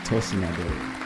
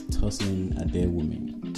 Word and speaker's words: Tosin Adeyemi: Tosin Adeyemi. You gotta put the accent. You just gotta do it. It's Tosin Adeyemi.